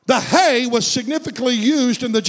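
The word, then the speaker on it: He: But in the Hebrew, it was called Hey, H-E-H, show slide number one. There it is. That's how you would pronounce the fifth letter of the alphabet.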